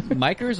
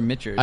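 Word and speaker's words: Michter's: Is it Mikers or Mitchers? – I think it's Mitchers. – I think it's Mitchers. Mitchers, yeah.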